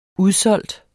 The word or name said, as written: udsolgt